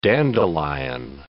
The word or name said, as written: dandelion